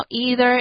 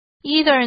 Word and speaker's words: either: either A or B 的用法 A 或 B 则一 either 呢可以念成 either 或是 either